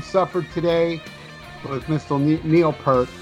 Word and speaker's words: Peart: I believe the correct pronunciation is Peart, but, you know, everybody outside of Canada calls him Neil Peart, so I'll accept either or.